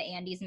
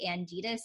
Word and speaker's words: Andes: That one thing I wanted to point out that I just thought was funny is they both mispronounced the Andes Mountains as the Anditas and then the Andreas Mountains.